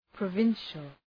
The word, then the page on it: provincial: prə’vınʃəl